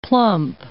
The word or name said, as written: plump